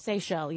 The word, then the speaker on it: Seychelles: Have you ever heard of the, I believe it's pronounced, is it pronounced Seychelles? – Seychelles, yeah. – The Seychelles? – Yeah. – The islands? – S-E-Y-C-H-E-L-L-E.